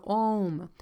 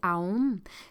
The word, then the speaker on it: Om: Le « Om », ou des fois est prononcé « Aum », c'est le son de l'univers.